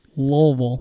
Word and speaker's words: Louisville: It's pronounced Louisville because she's, you know, Southern. – Louisville.